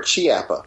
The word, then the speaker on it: Chiappa: Chiappa or Chiappa? – Yeah, it's Chiappa. It's pronounced with a K.